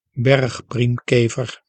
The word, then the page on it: bergpriemkever: berg·priem·ke·ver